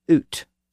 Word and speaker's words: out: So out was oot, spelled U-T, and thou was thu, spelled thorn U.